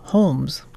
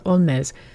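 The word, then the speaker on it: Holmès: Now, her father was Irish, and he might have pronounced the last name Holmes, kind of like Sherlock Holmes, but because she was born and raised in France, she pronounced it in a French manner, Augusta Olmez.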